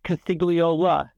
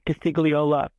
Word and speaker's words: Costigliola: My name is even longer than it seems. It's Costigliola. There's an extra syllable in there. Costigliola, as I pronounce it.